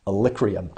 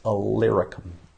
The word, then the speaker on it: Illyricum: Alicrium. I've always mispronounced it. And then one day I slowed down and looked at that word and thought, that is not Alicrium, it's Illyricum.